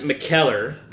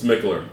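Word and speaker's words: Mikkeller: Okay, now I pronounce it McKellar, but apparently that's Mickler. – Wrong. – Okay. – It's Mickler.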